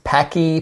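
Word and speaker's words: Paqui: There was a brand, I think it's pronounced Packy, P-A-C-Q-U-I, something like that.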